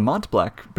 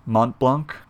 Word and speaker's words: Mont Blanc: I think it's Mont Blanc. – Mont Blanc? Yeah. – Because it's a French mountain. – Okay, fair. Mont Blanc. I feel silly saying that. Mont Blanc.